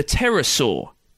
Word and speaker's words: pterosaur: The pterosaur, which is confusing to look up online because there's a silent P at the start, just thought I'd warn you.